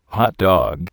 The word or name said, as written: hot dog